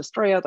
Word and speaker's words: Estrella: It's actually pronounced Estrella. That's the Spanish pronunciation.